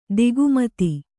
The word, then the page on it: ತಿಗ್ಮತೆ: tigmate; Kannada